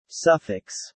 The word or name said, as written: suffix